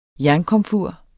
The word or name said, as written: jernkomfur